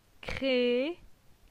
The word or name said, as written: Cre